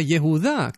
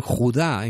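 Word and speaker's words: Yehudá (יהודה): Bueno, si leyéramos en hebreo, entenderíamos que justamente Yehudá, la palabra Yehudá, que dicen Judá en castellano, omitiendo una sílaba, Yehudá tiene al principio el nombre mismo del Señor.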